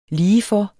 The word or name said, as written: ligefor